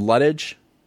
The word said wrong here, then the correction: Litke: Oh, Lucas Litke. – Litke? That's how they say it? – Yeah.